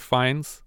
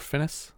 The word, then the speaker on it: Fiennes: He has a bunch of stuff, but the only title I recognized was Luther with Joseph Fiennes, or Finnis, depending on if you want to pronounce it wrong.